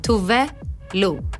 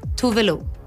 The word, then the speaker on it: Tove Lo: Hi, if there's still confusion around how to pronounce my name, this is how you pronounce it. Tuve Lu. Tuve Lu. My name is Tuve. Lu is my nickname. You put it together, Tuve Lu.